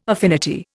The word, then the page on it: affinity: Indian